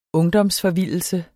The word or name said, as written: ungdomsforvildelse